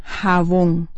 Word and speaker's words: jabón: La palabra jabón. Pronunciémela nuevamente. Jabón. La mayor fuerza de voz o acento fonético se centra en la sílaba bon.